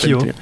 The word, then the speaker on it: Schio: C'était pas une semaine facile d'ailleurs, puisqu'elle s'incline également en Coupe d'Europe face à Schio, ou Schio, je sais pas comment tu le prononces en italien, puisque c'est Schio. – Schio.